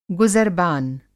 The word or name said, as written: गुज़रबान